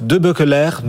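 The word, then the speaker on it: De Beukelaer: Christophe Debeukeleire nous rejoint également. Bonjour. – Bonjour Guillaume. – Ah, vous êtes scié, je l'ai bien prononcé. – Oui, vous m'impressionnez.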